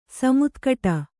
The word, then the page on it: ಸಮುತ್ಕಟ: samutkaṭa